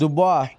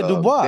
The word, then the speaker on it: Dubois: I can't pronounce Dubois. – Yeah. The fighter this weekend. – Yeah, Dubois.